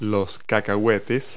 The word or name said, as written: peanuts